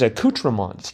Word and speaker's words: accoutrements: Accoutrements, A-C-C-O-U-T-E-R-M-E-N-T-S. Maybe this is just the Canadian in me, but I thought of it as accoutrements, T-R-E-M-E-N-T-S. – That's what I thought, too. Accoutrements, I always pronounce right.